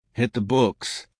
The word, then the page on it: hit the books: American English